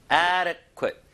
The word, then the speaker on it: adequate: adequate, adequate. 자, 앞에를 힘을 좀 주셔야 되는데 이 D가 살짝 리을 발음처럼 변하긴 변해요. 그래서 adequate, adequate, adequate weight.